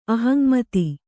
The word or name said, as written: ಅಹಂಮತಿ